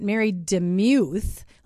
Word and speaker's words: DeMuth: She is, and she tells me this is how we pronounce it, Mary DeMuth. Like Mew, like a kitty.